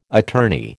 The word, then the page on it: attorney: American English